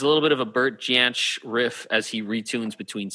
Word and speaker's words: Bert Jansch: I noticed Neil plays a little bit of a Bert Jant. I'm going to say it wrong. Bert Jansch. – Oh, Bert. Yeah, it's Bert Jansch.